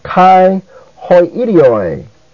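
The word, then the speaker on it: kai hoi idioi (καὶ οἱ ἴδιοι): Hoi is also a proclitic. That is, it goes so closely with the word that follows so as to have no accent of its own. So it's pronounced kai hoi idioi.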